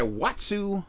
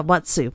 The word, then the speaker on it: Watsu: Now, you said you had a Watsu massage. Am I saying that right? – I did, yeah, Watsu.